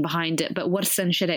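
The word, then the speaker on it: Warsan Shire: In Somali, the way we'd pronounce it is Warsan Shiro. So Warsan Shiro.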